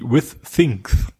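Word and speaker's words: Withings: Es ging darum um die Aussprache, weil es ist ja Französisch und sowas, aber nein, also Wikipedia sagt zumindest, With Things heißt eben, wird eben wie With Things quasi ausgesprochen und auch so gemeint, also mit Dingen. Als wenn da zwei THs wären.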